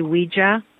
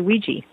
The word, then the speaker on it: Ouija: It's like Ouija. Some people say Ouija, uh, and most people say Ouija.